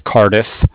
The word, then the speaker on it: Cardiff: Okay, it's Sam with Cardiff. Cardiff is spelled C-A-R-D-I-F-F, like Frank.